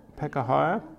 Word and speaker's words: Pekahiah: How would you say that? You're very good with your... Pekahiah, yep.